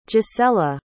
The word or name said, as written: gisella